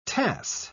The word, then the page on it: tass: British English